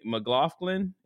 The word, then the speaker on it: McLaughlin: So I'm talking about Sydney McLaughlin.